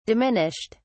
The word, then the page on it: diminished: British English